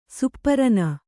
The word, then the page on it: ಸುಪ್ಪರನ: supparana